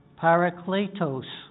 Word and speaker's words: parakletos: The term in the Greek is, hope I pronounce it right, parakletos. We close, Norm? – Pretty good.